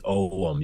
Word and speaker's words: ogham: Um, ogham isn't. Some people will correct me. They'll say it's oum. You don't say the guh.